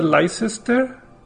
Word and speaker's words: Leicester: Universidad de Leicester.